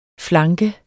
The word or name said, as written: flanke